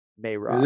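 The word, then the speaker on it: Merab: So we just found out, by the way, in our pronunciation of the week segment that it's Marab and not Marab. Marab. – It is Marab, yeah.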